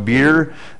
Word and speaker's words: bier: And so now you can imagine, as she's following behind this open coffin, by the way, B-I-E-R, which is pronounced beer, simply means an open coffin.